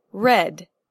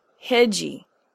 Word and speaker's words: read: Examples are read, out, eat, linked. Let's go through these one by one. Read is one syllable not pronounced hedgy.